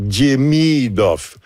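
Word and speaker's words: Demidov: Et si on veut être pointu, c'est die. Il y a un petit Diemidoff. L'accent est sur la deuxième syllabe, donc Diemidoff.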